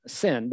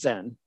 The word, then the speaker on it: Zin: We would pronounce it Sin, but it's Zin, which is between Elam and Sinai.